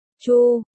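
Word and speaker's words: Chu (猪): Trong tiếng Trung con heo đọc là Chu, đồng thanh với Chu.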